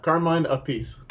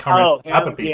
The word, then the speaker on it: Carmine Appice: Carmen, I always assumed I'm going to pronounce Carmine Appice. – Carmine Appice. I always want to say Carmen Appice.